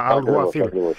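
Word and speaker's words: Alguacil: Το γάμα κάπα να βγει και να βγει το κάπα και να μείνει μόνο το γάμα. Αλγουαθίλ.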